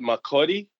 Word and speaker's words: Makurdi: Is it McCurdy? Is that the correct pronunciation? – Yeah, it's called Makurdi.